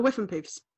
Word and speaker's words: Whiffenpoofs: I know that because how do you pronounce it? – The Whiffenpoofs. – Whiffenpoofs, thanks.